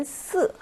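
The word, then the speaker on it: 四: 중국어에서 사의 발음은 스 하고 사성이에요.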